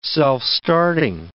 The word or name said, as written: self-starting